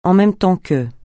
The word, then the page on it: en même temps que: français